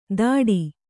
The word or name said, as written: ದಾಡಿ